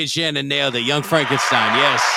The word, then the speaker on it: Frankenstein: Dr. Frankenstein. – It's Frankenstein. – You're putting me on. – No, it's pronounced Frankenstein.